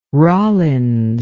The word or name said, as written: rollins